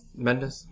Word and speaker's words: Mendes: Mendes.